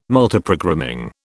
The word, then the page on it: Multiprogramming: British English